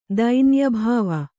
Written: ದೈನ್ಯಭಾವ

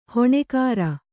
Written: ಹಾನಿಕರ

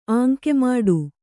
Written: ಆಂಕೆಮಾಡು

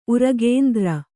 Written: ಉರಗೇಂದ್ರ

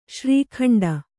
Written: ಶ್ರೀಖಂಡ

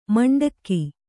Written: ಮಂಡಗೆ

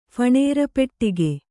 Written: ಫಣೇರಪೆಟ್ಟಿಗೆ

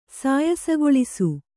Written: ಸಾಯಸಗೊಳಿಸು

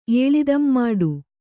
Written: ಏಳಿದಂಮಾಡು

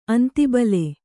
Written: ಅಂತಿಬಲೆ

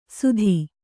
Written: ಸುಧಿ

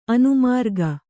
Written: ಅನುಮಾರ್ಗ

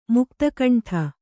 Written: ಮುಕ್ತಕಂಠ

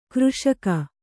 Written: ಕೃಷಿಗೈ

